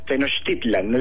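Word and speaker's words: Tenochtitlan: Tenochtitlán, las palabras en náhuatl todas se acentúan en la penúltima sílaba, aunque los españoles lo deformaron todo, y después hay muchas muchos pueblos que han cogido un nombre incorrecto.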